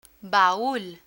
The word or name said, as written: baul